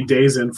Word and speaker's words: DAZN: DAZN. That's how that's supposed to be pronounced, just so you know. that D A Z N. Yeah, I don't know. It's going to be days in for for quite a while.